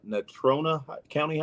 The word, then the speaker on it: Natrona County: She said that you played varsity at Natrona County High School.